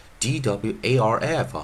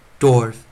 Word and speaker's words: dwarf: 像上一堂课我们就教到 d w a r f 啊，dwarf dwarf，它就是念 or，不是念 r。